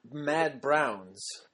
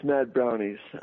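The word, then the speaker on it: Mad Brownz: So it's not Mad Browns. – No, actually, it's Mad Brownies. Okay. Actually, Mad Brownies. That is how I pronounce it.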